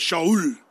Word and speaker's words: Shaul: But if you're in Israel and you want to talk about Saul, you have to say Shaul. That's how they say it, Shaul. So if you're talking about King Shaul, you'll think, oh, that's King Saul. Or Shaul, talking about Paul the Apostle. That's the Hebrew way of saying it.